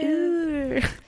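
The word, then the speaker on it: Óor: And Ur is spelled O-O-R, and there's a little accent on the first O. Yeah. – Ur. – Yeah, pretty much.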